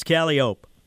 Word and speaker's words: Calliope: If you're going down St. Charles right now, as you know, right before Calliope or Calliope, if you're, you know, nah, come on, say it right. It's Calliope.